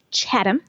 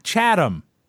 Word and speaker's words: Chatham: Some people would think that it might be pronounced Chatham. – Yes. Or Cheatham. – It's Chatham. – Chatham.